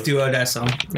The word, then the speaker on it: Duodecim: Duodecim is how it's pronounced, but it's 012 in, was it braces or...